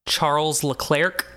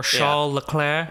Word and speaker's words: Charles Leclerc: My 2 is Charles Leclerc. – Okay. – As some Americans say, or Charles Leclerc. As apparently both are correct.